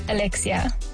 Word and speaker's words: Alexia: My name, which is Alexia, it's Greek. I really enjoy my name. I think back then, because no one could pronounce my name, it was either Alexa or Alexis.